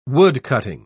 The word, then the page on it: woodcutting: British English